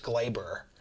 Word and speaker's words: Gleyber: Except Gleybar. Gleyber. I've read that it's pronounced Gleyber.